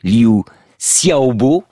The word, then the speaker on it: Liu Xiaobo: Nous venons de raconter l'histoire de Tiananmen, et nous avons cité pour terminer ce récit des vers de Liu Xiaobo.